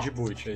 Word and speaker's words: Djibouti: um país bem pequeno ali que se chama Djibouti, eu não sei se eu tô falando certo. – Djibouti, é isso mesmo.